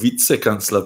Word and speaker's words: Vizekanzler: Das würde aber jetzt zu der Betonung von Vizekanzler passen. – Vizekanzler. – Ja. Ja, das sagen die ja wirklich so in Österreich, habe ich gemerkt, ja.